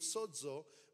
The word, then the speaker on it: sozo: In Strong's number G4982 is a word spelt S-O-Z-O. It's pronounced sozo.